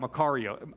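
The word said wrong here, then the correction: Makarios (μακάριος): I said that wrong. I had to like look up how to pronounce it this morning. Makarios is how you say it. Everybody say that out loud, makarios.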